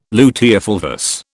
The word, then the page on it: luteofulvous: British English